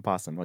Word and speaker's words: opossum: But this was an opossum. – An opossum. – Yeah. – Okay. – So why would they name him that and they don't even pronounce the O?